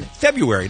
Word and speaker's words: February: We had a mini conversation on the air the other day about whether you pronounce the first R in February. Now, my whole life, I've just called it February and have been fine with that. But I have to tell you, Kevin, the response from the Kevin and Bean listeners overwhelmingly suggests that we should be pronouncing that first R.